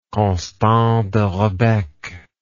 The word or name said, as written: Constant de Rebecque